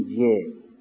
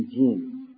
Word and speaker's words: جیم: ولی وقتی خواستیم تلفظ بکنیم نمیگیم جه، میگیم جیم.